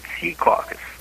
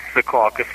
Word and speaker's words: Secaucus: He said Sea Caucus. – Secaucus. – It's Secaucus for us. – Secaucus. – Westerners. He pronounces it Sea Caucus.